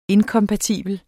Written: INKOMPATIBLE

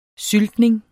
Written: Syltning

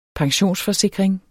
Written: pensionsforsikring

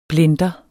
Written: BLENDEREN

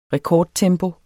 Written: REKORDTEMPO

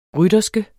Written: rytterske

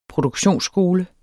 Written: PRODUKTIONSSKOLE

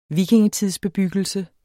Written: vikingetidsbebyggelse

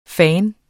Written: fandens

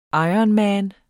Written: ironman

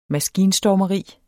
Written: maskinstormeri

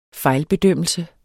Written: fejlbedømmelse

